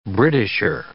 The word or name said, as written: Britisher